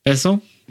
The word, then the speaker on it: Besson: Directed by Luc Besson. That's how you pronounce Luc. – It. – He's Jean-Luc. – French. Jean-Luc Besson. – Besson. – Yeah. Besson. Mm-hmm.